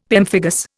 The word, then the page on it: pemphigus: Indian